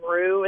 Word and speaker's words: Roux: There's a dog that's been coming recently named Rue. It's spelled like LaRue, R-O-U-X.